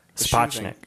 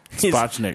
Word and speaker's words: Sapochnik: Sapochnik. – Sapochnik.